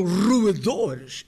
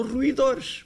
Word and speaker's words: roedores: E o conjunto de animais especializados em roer eram roedores e não ruidores, como nós dizemos. Que era a ridícula pronúncia deste bárbaro.